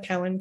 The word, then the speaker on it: Cowan: Best process would be they can reach out to me on LinkedIn, Paula Cowan, Career Transition Strategist.